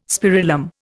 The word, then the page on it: spirillum: Indian